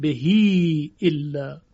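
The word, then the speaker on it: بِهِۦ: مثل بهی الا در کلمه بهی اگر به کتابت قرآن مراجعه بکنیم میبینیم که به نوشته یعنی یک با و یک های مکسور اما در تلفظ ما اون رو صله میکنیم به یا و میگوییم بهی الا خب پس این از قبیل منفصل هست که باید دقت کنیم در روایت دوری به توسط خونده شده در طرق مشهورش.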